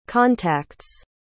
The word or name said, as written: contacts